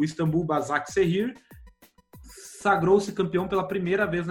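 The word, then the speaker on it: Başakşehir: Como que eu vou pronunciar isso aqui, meu Bazak Serrir. – Bazak Serrir.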